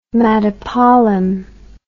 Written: madapollam

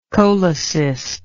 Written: cholecystic

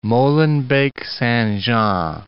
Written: Molenbeek-Saint-Jean

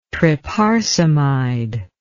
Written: tryparsamide

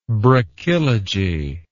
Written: brachylogy

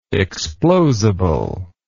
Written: explosible

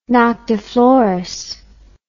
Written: noctiflorous

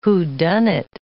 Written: whodunnit

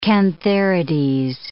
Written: cantharides

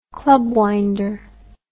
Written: clubwinder

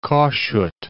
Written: Kossuth

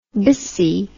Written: biccy

